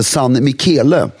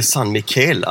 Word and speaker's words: San Michele: Ja, jag har alltid trott att det heter San Michel. Till och med, alltså, ja. Nej, men det heter San Michele alltså.